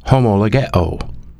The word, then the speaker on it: homologeo: The original word confess from the original Greek language is a compound word pronounced homologeo, and it means the same to name, the same to name.